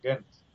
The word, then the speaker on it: Ghent: Así como se pronuncia. Como decir gente, pero sin la E. Ghent.